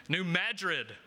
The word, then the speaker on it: New Madrid: now I'm not going to say this right, so this is how you know I'm from, not from there, but the New Madrid fault line? Madrid. Yep. New Madrid fault line.